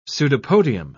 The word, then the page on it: pseudopodium: British English